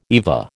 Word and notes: EVA: American English

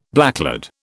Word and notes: blacklead: British English

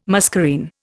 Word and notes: muscarine: Indian

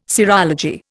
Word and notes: serology: Indian

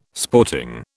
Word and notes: sporting: British English

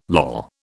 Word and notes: loll: British English

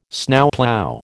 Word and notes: Snowplough: American English

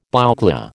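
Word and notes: bouclé: American English

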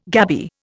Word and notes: gabby: Indian

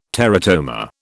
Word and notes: teratoma: British English

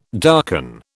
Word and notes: darken: British English

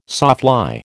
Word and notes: sawfly: American English

